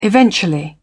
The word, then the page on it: eventually: British English